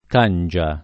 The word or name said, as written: cangia